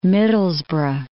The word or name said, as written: Middlesbrough